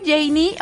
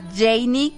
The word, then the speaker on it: Jenny: Por cierto, soy Janie. Oye, ya nos sigues en redes sociales, @ Radio Disney MX, @ Janie Capistrán.